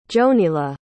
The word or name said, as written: jonila